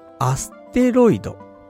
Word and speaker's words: 小惑星: 小惑星と書いて、ね、なんて読むのか。恋する、アステロイド。